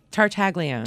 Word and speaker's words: Tartaglione: Tartaglione. – Tartaglione. Yes. I guess that's okay. – I guess I'm doing the Italian with the not pronouncing the G.